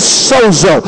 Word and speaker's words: sozo: That word saved in the Greek means sozo. It's S-O-Z-O in the Greek.